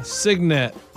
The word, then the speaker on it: signet: It's called a signet. Signet. – A signet? – It's spelled S-I-G-N-E-T, but it said signet.